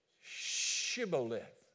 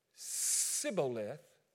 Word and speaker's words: Shibboleth: Say, Shibboleth. Shibboleth. And if the Ephraimite said, Sibboleth, because he could not pronounce the word correctly, he could not, for whatever reason. The Bible doesn't tell us why, but the Ephraimites could not say the S-H sound.